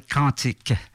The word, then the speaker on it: Quintike: Et c'est la première fois que j'avais fait une entrevue, probablement parce qu'il venait le présenter à Québec, cet album-là qui s'appelle Quantique. Q-U-I-N-T-I-K-E. On peut le prononcer comme quantique, la physique quantique, mais aussi comme un quantique.